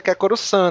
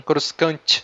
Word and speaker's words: Coruscant: Um nome meio francês também, né? Coruscant, Coruscant se escrevendo que fala Coruscant, né?